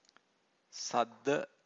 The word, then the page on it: sadda: Pali